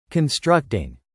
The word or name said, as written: constructing